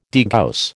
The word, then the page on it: degauss: American English